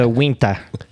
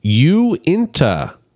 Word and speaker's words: Uinta: You both are wrong. It is actually Uinta. – Uinta, just like it's spelled? Uinta. – Yeah, that's why it has a big U there.